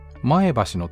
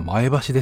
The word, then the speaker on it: 前橋: 天気予報で、前橋の天気はって言われるんですけど、地元の人は前橋なんて言ってる人いません。そこは前橋ですっていうね。 – イントネーションってこと？ – そこ大きく違うんですよ。